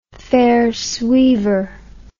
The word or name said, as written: faire suivre